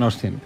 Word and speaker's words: Austin: Bueno, la A no la dicen. Me fijé Austin. Y no lo decían, decían Austin.